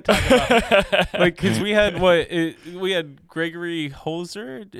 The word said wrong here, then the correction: Heisler: Am I pronouncing his last name right? Like the photographer? – Gregory Heisler? – Heisler. – Yes, I'm sorry.